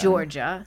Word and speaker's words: Giorgia: And Georgia, G-I-O-R-G-I-A. – Ooh, I like that spelling of Georgia. – But it'd be Georgia. – Yeah. – It'd be pronounced Georgia because G-I is just J.